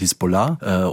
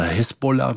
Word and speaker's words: Hisbollah: Wenn man es richtig arabisch ausspricht, dann wird es am Ende, auf der letzten Silbe betont, Hisbollah oder Hezbollah mit E dann auch.